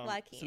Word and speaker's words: Joaquin: Yeah, Joaquin.